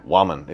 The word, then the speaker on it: Wuaman: Wuaman. – Yeah. – Wuaman.